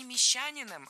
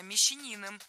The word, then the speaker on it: мещанин: Видимо, он не вписался в более богатое сословие купцов второй гильдии, стал мещанином, а, видимо, после этого дела совсем пошли плохие. – Стал не мещанином, а мещанином. Мещанин, ударяем третий слог. Хотя во множественном числе мещане ударение падает на второй слог.